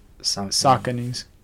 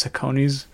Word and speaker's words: Saucony: On their website, they tell you how to pronounce it, and it's Sauconys. Yeah. I've always heard it as Sauconys, but I don't know.